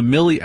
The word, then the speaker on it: Millie: But me, personally, I'm a I'm a Mealy. How do you pronounce her first name? Miley or Mill? No, I'm a Millie Alcock girl.